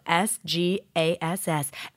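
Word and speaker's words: Sgass: The wine is 2017 Sauro Mall, the S-Gas, that's what it's called, and it's spelled S-G-A-S-S, S-Gas, and it's from the Veneto in Italy.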